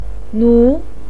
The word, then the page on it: nu: 2